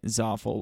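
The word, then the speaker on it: Zaful: and name it Zoffel, which rhymes with awful?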